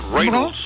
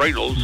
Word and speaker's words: Reynolds: Ryan Reynolds, note la pronunciación. Reynolds, ¿no? Reynolds.